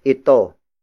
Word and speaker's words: ito: Nabasag is spelled as N-A-B-A-S-A-G. It means broke, or the event of breaking has happened. And then it is followed by ito. Ito. Ito. Ito is spelled as I-T-O.